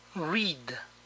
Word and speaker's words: read: Leer, read. En el pasado se escribe igual, pero se pronuncia diferente. Read.